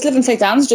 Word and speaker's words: Lytham St. Anne's: Because do you live in, is it Lytham St. Anne's, is that how you say it? – Yes, so it's Lytham St. Anne's, just outside Blackpool.